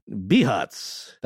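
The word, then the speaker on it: Bihać: Bihac. – That's a town? – That is spelled B-I-H-A-C with a accent mark over it.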